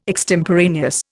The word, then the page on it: extemporaneous: Indian